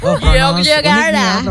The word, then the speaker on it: Jogjakarta: Itu pronounce apa itu? – Jogjakarta. Pronounce-nya ya.